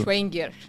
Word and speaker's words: Schevenger: C-H-B-E-N de navio G-E-R. Como que se pronuncia, Ju? Schwenger.